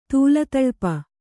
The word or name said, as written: ತೂಲತಳ್ಪ